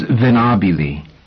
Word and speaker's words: Venabili: "Dawes Venabili." – She spelled the last name and emphasized the stress on the second syllable.